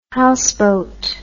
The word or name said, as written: housebote